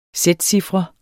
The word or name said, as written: sætcifre